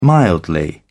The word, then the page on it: mildly: British English